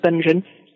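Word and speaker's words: Będzin: That's how you pronounce what looks like Bedzin to you and me, but it's pronounced Benzin.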